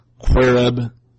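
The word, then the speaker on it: qereb: If you look it up, it's Q-E-R-E-B, basically. Of course, it's Hebrew, but that's how we would basically pronounce it, I guess. Quereb, something like that.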